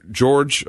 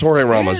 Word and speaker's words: Jorge: And Univision anchor George, or is it George or Jorge? How did you pronounce his name? Jorge Ramos? – I think so. – It's Jorge Ramos.